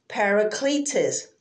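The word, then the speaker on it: parakletos (παράκλητος): And if we break that down in the Greek, it is pronounced parakletos.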